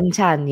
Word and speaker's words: banchan: Banchan, am I saying that correctly? – Banchan, yes, banchan. Very good.